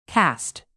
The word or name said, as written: cast